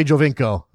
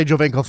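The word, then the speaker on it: Giovinco: And I'm being distracted by being told that I'm pronouncing that new Italian player in Toronto's name wrong, and I better get it right. So I don't want to piss anybody off, Aaron. So I should say Jovinko, okay? I'll say Jovinko from now on.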